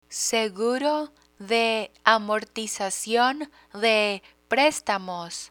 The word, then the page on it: seguro de amortizacion de prestamos: spanish